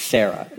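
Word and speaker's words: Serah: Asher also had a daughter named, it's Sarah, is how you say that name.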